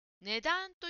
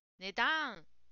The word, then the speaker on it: 値段: それから答えの文の値段という言葉ですが、ジムさんは値段と発音していました。ちょっと英語みたいな発音になっていますね。値段とあまりだのイントネーションが強くならないようにしましょう。